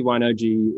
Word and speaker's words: Wine-OJI: And I am pronouncing that correctly, right? – Yeah, you're pronouncing it correctly. Okay. Absolutely. – Excellent. – It is. So, thanks. Basically, Wine-OJI is